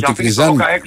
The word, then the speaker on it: Τικνιζάν: Τικνιζιάν και τον Ρόκα. – Ο Τικνιζιάν με το Τικνιζάν, γιατί δεν προφέρεται, λέει, είναι μένει με το Α. Τικνιζάν, λοιπόν, να το λέμε.